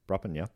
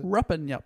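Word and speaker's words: Rupanyup: Ruppinyup. No, I didn't say that right, what was it? – Ruppinyup. – Ruppinyup. Beautiful part of the world.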